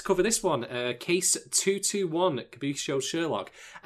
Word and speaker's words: Kabukicho: The K word. – Kabuchiko. Oh, Kabuki. Yeah, eh. Krispy Kreme. Krispy Kreme. – I do it all the time too. Kabukicho. Kabukicho. Kabukicho.